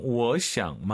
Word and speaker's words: 我想: 我想妈妈。 – 삼성삼성이면 앞에가 이성. 我想 마마. 이걸 기억하시면 좋을 것 같습니다.